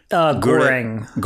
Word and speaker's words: Goreng: Goreng. – Goren? – It's spelled G-O-R-E-N-G. – Okay. – I think it is pronounced Goren, though.